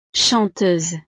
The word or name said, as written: chanteuse